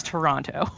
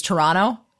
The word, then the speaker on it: Toronto: The funny thing about Toronto to me is that they actually pronounce it the way that the Canadians pronounce it, which is Toronto instead of Toronto.